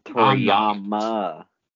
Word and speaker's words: Toriyama: You said it wrong again. – God damn it. – Don't disrespect the good goddamn name of Akira Toriyama, sir. – Toriyama. – Say it with me. Tori? – I'm not saying it.